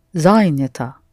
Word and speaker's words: занята: Я сказала, что занята. – Mm-hmm. And say я as well. – Я сказала, что я занята. – Mm-hmm. Only with the feminine of this word, the accent falls on the ending.